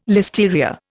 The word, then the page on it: listeria: Indian